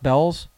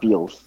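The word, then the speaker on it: Bealls: Is that how it's pronounced, Bell's? – Yeah, not Beals.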